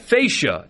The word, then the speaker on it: fascia: Fascia is how it's pronounced.